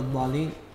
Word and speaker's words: الضالين: الضالين، المبالغة في تشديد اللام من نفس الكلمة وتعليق اللسان عند النطق باللام، والصواب نطقها كحرف مشدد عادي دون مبالغة أو تعليق اللسان.